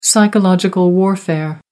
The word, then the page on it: psychological warfare: American English